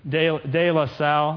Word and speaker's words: De La Salle: Well, it's about a football team, De La Salle, that's how you say it, and Concord.